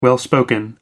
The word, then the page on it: well spoken: American English